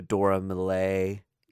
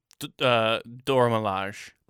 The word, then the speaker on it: Dora Milaje: One of which was the, I always say this wrong, the Dora Milaje. – Dora Milaje. – Milaje, fighting the Black Panther.